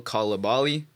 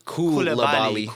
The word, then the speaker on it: Coulibaly: Bilal Coulibaly. – You butchered that last name. – Yeah, I did. Coulibaly. My bad.